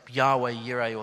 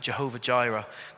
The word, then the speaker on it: Yahweh Yireh: And he was talking about the phrase Yahweh Yireh, or sometimes we pronounce it Jehovah Jireh.